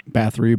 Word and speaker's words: Bathory: And then Elizabeth Battery, whatever how they say it. Bathory.